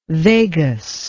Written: vagus

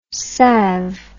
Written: sav

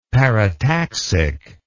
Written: parataxic

